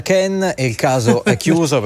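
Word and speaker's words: Kean: C'è tutta una questione, perché allora in un'intervista di qualche settimana fa, di qualche mese fa, il padre ha detto no, si dice Ken. Poi lui, l'interessato, prima ha detto pronunciatelo come volete, e in un'ultima intervista in Inghilterra ha detto mi chiamo Kin.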